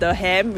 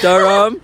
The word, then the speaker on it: Durham: I used to pronounce it Durham, which is not. It's supposed to be Durham.